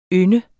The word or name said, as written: ynde